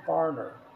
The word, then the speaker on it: Farriner: And then it says here, working late in his bakery, Thomas, and I have trouble pronouncing this, but it's spelled F-A-R-R-I-N-E-R. So every time I pronounce it, it sounds like foreigner. So it sounds like foreigner, like someone who is from a foreign country, but it's actually foreigner.